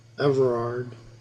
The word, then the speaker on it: Everard: Well, my full name is Willard Everard Stone, and I was born in Germantown, Pennsylvania, on August 28th, 1910.